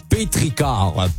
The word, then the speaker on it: pétrichor: Bon, c'est pas un pétrichor, là, juste à côté, c'est marqué, ça se prononce pétrichor.